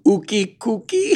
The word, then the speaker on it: Ookie Cookie: Ookie rhymes with cookie. – Nope. I was trying to make it rhyme. – Ookie Cookie? – Yeah.